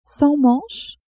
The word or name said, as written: sans manches